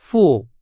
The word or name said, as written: 付